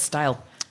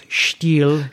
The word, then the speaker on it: Stihl: Is that how you say it? I always thought it was style. – It's Stihl. – Stihl. Let's be accurate. – Pronounce it right.